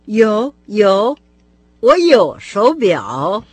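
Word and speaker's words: шоу бяо (手表): По общему правилу, третий тон, с которым произносится «шоу», перед «бяо» меняется на второй. – «Шоу бяо», «шоу бяо», «шоу бяо».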